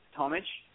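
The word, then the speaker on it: Tomić: Depends on part of the world you're in. If Eastern Europe, it's Tomic. Obviously with the Serbian Croatian pronunciation of the IC at the end.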